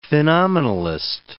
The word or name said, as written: phenomenalist